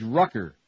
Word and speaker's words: Rucker: police also said an arrest warrant's been issued for former priest George Rucker, rhymes with all-day sucker.